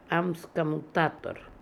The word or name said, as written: Amskomoutator